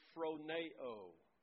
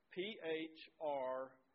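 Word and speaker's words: phroneo (φρονέω): The Greek word that I'm talking about is the Greek word phroneo. Phroneo. And it's a long O on the end. Phroneo.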